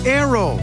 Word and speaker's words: Naoero: This program is previously called the country Nauru, N-A-U-R-U. The 11,000 residents have altered Nauru to be now Aero, N-A-O-E-R-O. They're going back to the pronunciation of their native language and losing a pronunciation used by outsiders.